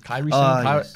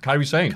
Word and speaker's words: Kairi Sane: Kairi Sane. Kairi. I'm getting the pronunciation correct. Kairi. Probably not. Kairi Sane.